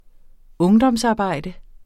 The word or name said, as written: ungdomsarbejde